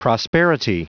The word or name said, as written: prosperity